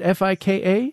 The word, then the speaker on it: fika: Fika, am I saying that right? Fika? – Yeah, so important for Swedes.